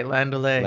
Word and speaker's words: Landaulet: Say it right. Landaulet. – Landaulet. – It's like Land O'Lakes, but with Landaulet.